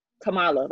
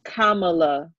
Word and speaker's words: Kamala: It's very easy to look at it and be like, Kamala. Like, people are like, oh, Kamala Harris, Kamala Harris, Kamala Harris. And it's Kamala. Like, Kamala.